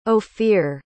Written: ophir

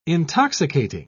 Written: intoxicating

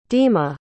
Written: dima

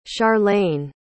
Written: charlaine

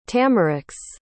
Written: tamarix

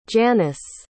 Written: janus

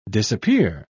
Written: disappear

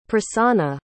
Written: prassana

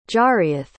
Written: jariath

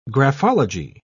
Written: graphology

